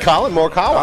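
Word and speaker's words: Collin Morikawa: Colin Morikawa.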